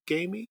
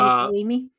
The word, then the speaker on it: Ghaemi: Gamey? – I think it's Hadi Gimi. – Yeah, it's spelled G-H-A-E-M-I. Hadi is H-A-D-I. Again, Gimi, G-H-A-E-M-I, the executive director of the New York-based Center for Human Rights in Iran.